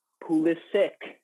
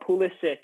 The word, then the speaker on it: Pulisic: If you doubt that soccer is a fun sport to watch, I plead you to go watch the baby Jesus senior, Christian Pulisic, not Pulisic. Guys, he wants the American pronunciation.